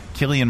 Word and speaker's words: Cillian: How do you pronounce her name? – Cillian Murphy. – Cillian. Okay. Cillian Murphy.